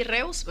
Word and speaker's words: Reuss: En redes estoy como Betsy Reus, por eso es bien importante decir mi nombre como se escribe Reus.